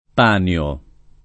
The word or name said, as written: Panio